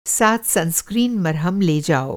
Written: सनस्क्रीन

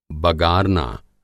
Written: बगारना

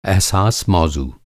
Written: अहसास

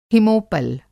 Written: हिमोपल

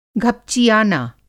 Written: घपचिआना

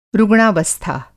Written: रुग्णावस्था

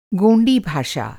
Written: गोंडी

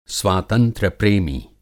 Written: स्वातंत्र्य-प्रेमी

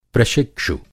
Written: प्रशिक्षु

